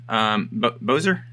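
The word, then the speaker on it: Boser: Bozier, am I saying it right? Yeah. Yep.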